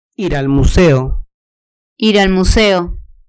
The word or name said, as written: ir al museo